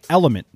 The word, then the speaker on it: LMNT: Today's show is sponsored and hydrated by Drink Element. That's spelled L-M-N-T, which I've been drinking daily now for weeks.